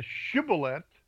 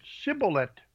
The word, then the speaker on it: shibboleth: All right, it is shibboleth, mm-hmm. – They said shibboleth. – Mm-hmm. – So they couldn't get that H out.